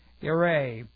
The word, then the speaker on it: yare: And as the Hebrew word is yireh, and it looks like this in the Hebrew, and we'll just spell that Y-A-R-E, yireh.